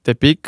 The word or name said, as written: Tepic